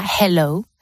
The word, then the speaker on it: hello: Entonces, ¿cómo hay que pronunciar esa H? – Entonces sería hello. Así hello. – Como dejando un poquito, como si nos calentáramos un poquito las manos, ¿no? Con el aire sale, pero nada más.